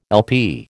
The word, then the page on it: LP: American English